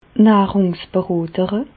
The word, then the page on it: d' Nàhrùngsberotere: Bas Rhin; Reichshoffen